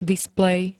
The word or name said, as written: displej